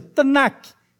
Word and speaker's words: Tanakh: Da pronúncia, Tanakh, é o T, u N u K.